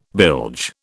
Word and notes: bilge: British English

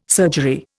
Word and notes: surgery: Indian

